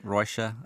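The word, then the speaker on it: Róisín: Now, I'm always very iffy about pronunciation of Irish names because the pronunciation bears very little resemblance to the spelling, but I'm going Roisin and Frank. – Yeah, I think that's correct. I think Roisin is how I've been saying it.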